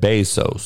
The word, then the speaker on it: Bezos: Andrew Arnold said Bezos, so that's how you pronounce his name.